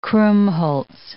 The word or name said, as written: krummholz